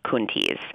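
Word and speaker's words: Coenties: and he told Youngerman that it should be pronounced Kuntes. But Coentes has stuck.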